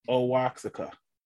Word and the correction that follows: Oaxaca: Is it Osaka? Oaxaca. – I think that's it. Oaxaca. – Yeah. – Oaxaca. – Yeah, we butchered that.